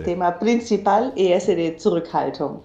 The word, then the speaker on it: Zurückhaltung: decirlo de nuevo, Zurückhaltung. – Perfecto, con la pronuncia perfecta.